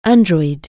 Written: android

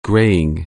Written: graying

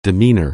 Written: demeanor

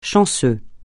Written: chanceux

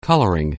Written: coloring

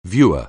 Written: viewer

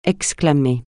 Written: exclamer

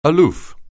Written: aloof

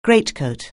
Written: greatcoat

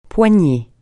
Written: poignée

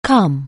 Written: come

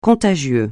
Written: contagieux

